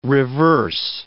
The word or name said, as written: reverse